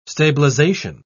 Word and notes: stabilisation: British English

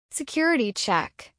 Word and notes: security check: American English

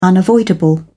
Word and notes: unavoidable: British English

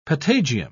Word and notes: patagium: British English

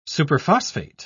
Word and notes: superphosphate: British English

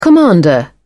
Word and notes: commander: British English

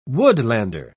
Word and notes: woodlander: British English

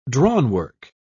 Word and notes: drawnwork: British English

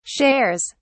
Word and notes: shares: British English

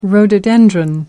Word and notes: rhododendron: American English

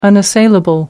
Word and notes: unassailable: American English

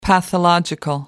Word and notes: pathological: American English

